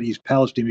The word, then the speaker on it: East Palestine: But by, by the way, I'm pronouncing it East Palestine because that's how the way the locals do.